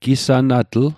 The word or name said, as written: gisanatl